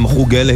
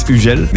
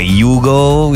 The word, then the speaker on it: Hugel: Hi guys, I'm Hugel in Greece. In French it's Hugel. The English going like Hugo, you know, then I have the German going like man Hugel. Then I have the Spanish saying ah Hugel papa.